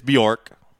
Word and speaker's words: Bjork: You're right. It's Bjork.